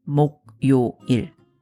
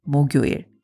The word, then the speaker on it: 목요일: If you only looked at the Hangul, you would be tempted to say 목요일, when in fact, natives pronounce it 목요일.